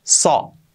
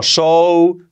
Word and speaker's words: saw: Y en pasado no es seed, no, es saw. He saw. Saw. See, saw. See, saw. ¿Vale? Saw. He saw you last week. Un error típico, este verbo, pronunciarlo como show, su, su, no. Saw.